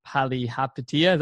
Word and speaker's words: Palihapitiya: And I know that one of the things you said is being selfish on your interview with billionaire Chamath Palihapitiya. Is that how you say it? – That's right.